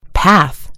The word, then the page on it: path: American English